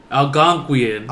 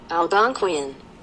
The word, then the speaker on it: Algonquian: All right, this is how you actually say it. – Algonquian. – I was way off. This is the proper way to say it. – Algonquian. – Okay, that's how you say it.